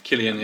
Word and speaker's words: Cillian: Cillian, yeah.